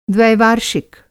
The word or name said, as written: द्वैवार्षिक